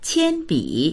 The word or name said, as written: qian1bi3